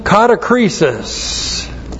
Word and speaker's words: katakrisis: Looks like that in the Greek, D-I-A-K-O-N-I-A. Diakonia is ministry, and then katakrisis. Katakrisis, K-A-T-A-K-R-I-S-I-S. Katakrisis, one. Now, katakrisis comes from the verb katakrino. Looks like that in the Greek, K-A-T-A-K-R-I-N, long O, katakrino.